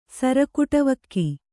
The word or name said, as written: ಸರಕೂಟವಕ್ಕಿ